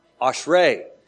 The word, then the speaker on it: Ashrei: Two syllables, Osh. And just think of your Uncle Ray. Osh Ray. But remember, where do we accent it? Osh Ray.